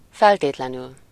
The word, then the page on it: absolutely: American English; /ˌæb.səˈlut.li/